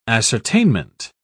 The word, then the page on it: ascertainment: British English